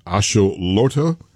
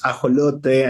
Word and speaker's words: ajolote: Yeah, well, your pronunciation was kind of spot on, so no worries about that. In Spanish, it's, you know, I think it sounds nice, right? It's ajolote, and it's a, and it's an endemic amphibious animal from Mexico.